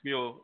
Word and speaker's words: Smule: Yeah, I was watching some of your, when your takeover for, I think it's pronounced Smole, a Smole or Smole? – Yeah, Smule, yeah. – Smule, yeah, mm-hmm.